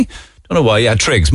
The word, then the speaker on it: Triggs: Is it Trix? Why did I think it was Trigger? Because I'm an idiot, I suppose. I know that many people have dogs. Triggs? Spell it. T-R-I-G-S? T-R-I-G-G-S? All right, okay.